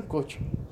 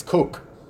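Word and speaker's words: Koch: Coach ka jo chicken hai na, coach. It's not coach, it's coke. It's coke. It's K-O-C-H, but it's pronounced coke.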